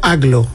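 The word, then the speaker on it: Agloe: No, entiendo que Aglo, yo lo he escuchado pronunciado como Aglo.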